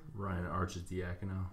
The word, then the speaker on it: Ryan Arcidiacono: Ryan Arcidiacono.